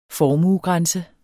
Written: formuegrænse

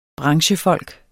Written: branchefolk

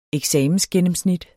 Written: eksamensgennemsnit

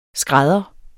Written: skrædder

